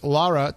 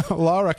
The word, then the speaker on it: Lara: Well, we have to take a break, and we are talking with Lara. Did I say that right? – Yes, Lara Calhoun of Resurrection Talent LLC.